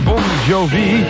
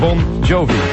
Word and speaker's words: Bon Jovi: Maar de officiële uitspraak is dus Bon Jovi.